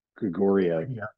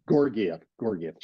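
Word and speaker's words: Georgiev: Help me with the name, because I'm going to mispronounce it when I say Gregoria. – Gorgiev.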